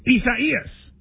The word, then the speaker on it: Isaias: It looks like Isaiah, but without the H. – Spell it. Do you spell it for me? – I-S-A-I-A-S. – And it's four syllables. I do know that. I-S-A-I-A-S. And it's like. – I-S-A-I-A-S.